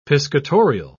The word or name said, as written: piscatorial